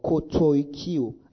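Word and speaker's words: katoikeo: Now, the word dwell here is the word katoikio or kotoikio. I spell it for you. K-O-T-O-I-K-E-O. K-A-T-O-I-K-E-O. Katoikio.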